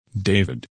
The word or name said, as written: David